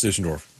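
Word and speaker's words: Düsseldorf: It is pronounced Dusseldorf. I was in Germany 2 months ago. That's exactly how they would have said it.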